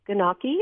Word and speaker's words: gnocchi: Beef cheek mushroom gnocchi. Am I saying that right? – Gnocchi. – Gnocchi.